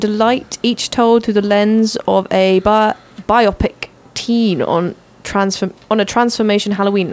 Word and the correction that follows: BIPOC: BIPOC. I said that wrong. Sorry, guys. It was BIPOC.